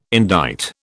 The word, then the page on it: indict: British English